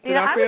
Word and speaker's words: D'Onofrio: I would send this to the Vincent D'Onofrio. I can't say his name. – Vincent D'Onofrio. D'Onofrio.